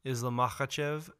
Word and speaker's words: Islam Makhachev: Very interesting bout between Islam Makhachev versus Thiago Moises.